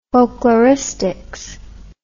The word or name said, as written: folkloristics